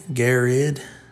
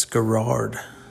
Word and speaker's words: Garrard: So anybody outside of Kentucky, would say that's spelled, you know, maybe G-A-R-R-E-D. Maybe Garrid. But it's Garrard. What is Garrard? G-A-R-R-A-R-D.